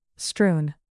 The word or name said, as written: strewn